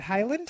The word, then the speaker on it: Haaland: Haaland.